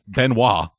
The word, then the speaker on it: Benoit: Did you say the noir thing? – I thought I pronounced it right. – It's Benoit. – Yeah, so I thought I said Benoit, but okay. I guess I'm pronouncing it wrong anyway. – Yeah, there's no R in it.